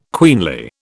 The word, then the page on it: queenly: British English